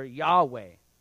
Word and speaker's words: Yahweh: We pronounce it, or it's transliterated, which is on the wall right there, Yahweh.